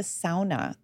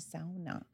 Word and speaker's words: sauna: It was in the backyard, and they called it a sauna. They said we were pronouncing it wrong. It was actually sauna. – Sauna. – Finnish people say sauna.